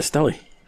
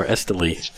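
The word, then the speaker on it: Estelí: They're in Esteli. – Oh. – Or Esteli, depending on how you Esteli. – Pronounce it.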